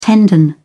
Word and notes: tendon: British English

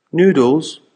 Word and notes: noodles: American English

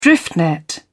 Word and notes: drift net: British English

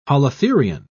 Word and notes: holothurian: British English

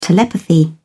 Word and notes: telepathy: British English

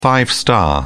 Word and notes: five star: British English

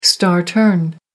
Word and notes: star turn: American English

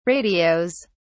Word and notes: radios: British English

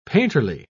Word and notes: painterly: British English

